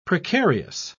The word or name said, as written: precarious